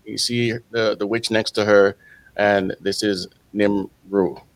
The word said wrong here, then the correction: Nimue: I've heard somebody pronounce it Nimue. – So. Nimue. There it is.